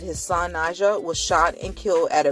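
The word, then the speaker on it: Nadji: Najah, as I'm saying his name right, it's spelled capital N, A as in girl, Apple, D-J-I, Rivera, was shot and killed last August in Tampa.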